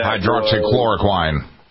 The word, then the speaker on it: hydroxychloroquine: That hydroxychloroquine.